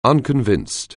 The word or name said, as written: unconvinced